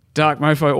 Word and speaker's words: Dark Mofo: Dark Mofo also got cancelled. Is that what it's called? – Yes. – Did I say that right? Good.